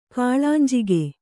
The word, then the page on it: ಕಿಳಿಂಜಕ: Kannada